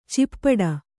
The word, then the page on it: ಚಿಪ್ಪಡ: cippaḍa